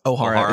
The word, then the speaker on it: Ohara: Ohara is how it's pronounced. – Okay. Oh, I saw it as a C, not an O. Okay. – Yeah, it's Ohara.